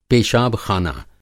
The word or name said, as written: पेशाब-ख़ाना